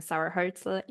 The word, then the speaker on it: Sarah Hoelzl: And just quickly introducing myself, I'm Sarah Hoelzl.